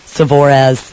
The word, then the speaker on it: Savorez: Again, you can see on his Facebook page, Savorez, that's S-A-V-O-R-E-Z, is how it is phonetically pronounced, and so you can find them on Facebook.